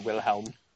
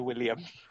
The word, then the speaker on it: Wilhelm: Like, he was clearly not a German actor because he mispronounces Kaiser Wilhelm. – Okay. – One of them says it right, and he calls him Kaiser William.